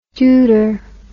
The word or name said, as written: deuter